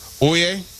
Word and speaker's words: Oia: And let me tell you, if you go to Santorini, my suggestion would be to stay in the Oie, which is spelled O-I-A.